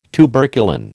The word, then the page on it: tuberculin: American English